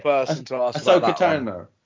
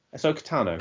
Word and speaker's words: Ahsoka Tano: Well, how do you say it? I've literally been saying it all day. – Ahsoka Tano. Ahsoka Tano. – Ahsoka Tano. – There you go.